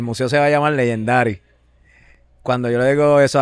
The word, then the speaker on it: Legendaddy: Añade solamente el y se pronuncia igual que en inglés, pero es Legendary.